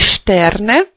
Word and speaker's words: Sterne: Опять же, сочетание «st» вначале читается как «st». Die Sterne.